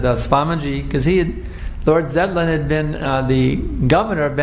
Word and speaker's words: Zetland: And he was preaching to one British lord, Lord Jetland. Or actually Lord Zetland.